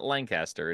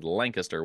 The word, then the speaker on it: Lancaster: The pronunciation of Lancaster, it is not Lancaster, it is Lancaster when you're in Pennsylvania.